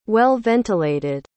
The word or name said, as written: well-ventilated